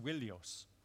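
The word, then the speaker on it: Wilios (Ϝίλιος): But Ilios has lost its W, its digamma from ancient Greek. Originally it would have been pronounced Wilios.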